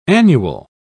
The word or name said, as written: annual